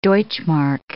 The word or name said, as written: deutsche mark